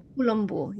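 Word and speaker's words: Columbu: Colombo. Did I say that right? I probably did not. – Yes. – Colombo. Yes. Okay.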